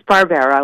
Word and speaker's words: Barbera: Uh, we're talking to, and by the way, is is the last name Barbara or is it Barbera? Am I pronouncing it correctly? – It is Barbera, Barbera. – like Hanna Barbera.